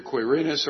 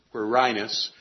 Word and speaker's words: Quirinus: It can be pronounced either Quirinus or Quirinus. Both of those are acceptable pronunciations. Q-U-I-R-I-N-U-S.